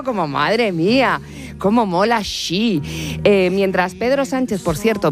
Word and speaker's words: Xi: Xi. – Es Xi, Xi.